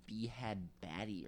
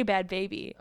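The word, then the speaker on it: Bhad Bhabie: I thought it was like B-Had Batty or something. – It's spelled with an H, but like you just say Bad Baby.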